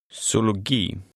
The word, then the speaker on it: Zoologi: In Swedish, a Z has an S sound. Try to read number six, which has a hard G sound. – Zoologi.